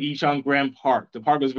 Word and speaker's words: Incheon: Incheon? – Incheon, yeah.